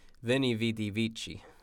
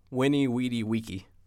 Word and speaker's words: Veni, vidi, vici: Well, most people say, Veni, vidi, vici.